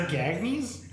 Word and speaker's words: Gagnes: The Gagnes? – See, this time I said it right.